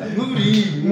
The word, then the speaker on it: Mourinho: Estaba Mauriño en el Madrid. Mauriño. – Que tiró 2014. – Mourinho. – Mourinho, Mourinho. – Mourinho, perdón, perdón.